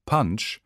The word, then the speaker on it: Punch: Englisch ausgesprochen, nämlich Punch, bedeutet das Wort Punsch nichts anderes als auf Hindi 5.